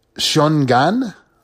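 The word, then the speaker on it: Xiong'an: It's a freeway that links Beijing and Xiongan.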